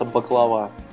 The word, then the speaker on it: баклава: Э, пахлаву, Э, которая у них называется баклава. По-гречески она баклава. Пахлава. У нас она называется пахлавой только исключительно потому, что она пишется МБ, у них мю, пи, значит, читается как Б.